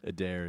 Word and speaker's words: Eder: Is it Eder? Adair? – Adair, yeah.